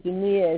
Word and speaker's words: Jinniya: It's the Jinniya, And it's spelled J-I-N-N-I-Y-A, Jinniya.